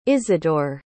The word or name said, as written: isidore